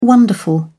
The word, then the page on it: wonderful: British English